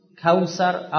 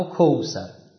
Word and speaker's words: کوثر: انا اعطینا کل کوثر او کوثر، واو ساکن ما قبل مفتوح، میتونیم مضموم هم تلفظش بکنم تو عربی، قوم قوم یوم یوم کوثر یا کوثر، یعنی همش خیره دیگه.